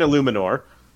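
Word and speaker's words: Eluemunor: It's Jermaine Illuminor. – Illuminor, yeah. Is that really how it's pronounced, or are you just guessing? – No, I know that's how it's pronounced.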